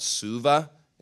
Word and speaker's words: Suva: I think that they went to a city called Suva, is how you pronounce it, and they went to the downtown area, and they got like over 120 people saved in a period of a week.